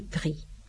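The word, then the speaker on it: pris: Y otro grupo termina el participio pasado en S, que no se pronuncia. Por ejemplo, mi y pri.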